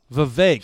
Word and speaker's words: Vivek: Vivek. So he said it rhymes with cake.